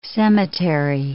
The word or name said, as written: cemetery